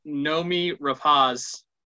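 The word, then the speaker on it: Noomi Rapace: I did not know how to say her name. And the reason why I know that I said it correctly is because I looked up a video of her saying it herself. So unless she's lying, then, I pronounced Noemi Rapace correctly.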